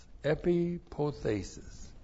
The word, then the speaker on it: epipothesis: E-P-I-P-O-T-H. Long E-S-I-S, epipothesis.